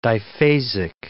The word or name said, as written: diphasic